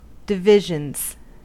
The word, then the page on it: divisions: American English